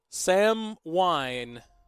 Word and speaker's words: Samhain: Samhain. I guess it would have been... – Very good. – Yes. – Very good with the fancy pronunciation. Correct. The proper pronunciation.